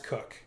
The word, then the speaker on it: Coke: Now, Edward Coke, it's spelled C-O-K-E. It's pronounced Cook.